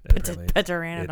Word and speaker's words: Pteranodon: Pteranodon. – It's a silent P.